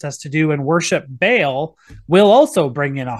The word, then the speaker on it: Baal: of either baal or baal however you want to pronounce that it's um, more faithful to the hebrew to pronounce it baal